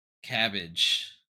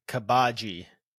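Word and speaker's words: Cabaji: Buggy's second mate, Cabbage, the acrobat. – I believe it's pronounced Kabaji. – Kabaji. – Yeah, because Cabbage. It's like a J-I.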